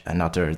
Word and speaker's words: Anutter: I do think that they are a group to watch, Anutter. They are, I think they're actually pronounced another. They've just removed the H and the E from the name, because that's what we do now.